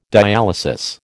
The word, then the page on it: dialysis: American English